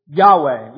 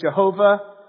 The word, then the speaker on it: Yahweh: Yahweh is the most likely translation. In past, had been thought to be translated Jehovah. But don't get all hung up on the pronunciation. Sometimes people get weird about that. That you can use Jehovah if you want.